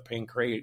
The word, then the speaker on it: Pancration: In the 40s, the Pancrat, I can't pronounce bad, but the Pancration.